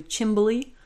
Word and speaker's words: chimney: So chimney also has a few different pronunciations, most notably chimbley, if you've ever heard that.